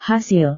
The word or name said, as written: hasil